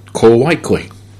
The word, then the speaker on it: Kowaikoi: Is that how you say it? – Kowaikoi.